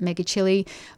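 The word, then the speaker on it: Megachile: But she said the one thing that she thinks is pretty cute is that many megachili, so I think here Kit pronounces it megachili, but I think in America they pronounce it megachili. So there's a couple of different pronunciations.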